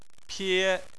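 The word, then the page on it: pie: tone 1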